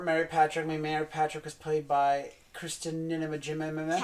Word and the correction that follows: Najimy: Kathy Najimy. – Thank you. I never say her name right.